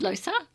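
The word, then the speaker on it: Loesser: How do you say that? – I think it's Lusa. Yeah.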